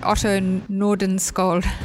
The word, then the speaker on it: Otto Nordenskjöld: This one by, I'm probably not going to say this right, Otto Nordenskjöld. – That was a good attempt.